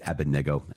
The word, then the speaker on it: Abednego: I always go back and forth with Abednego and Abednego. It's Abednego, I hear, because I believe the accent is on the third syllable.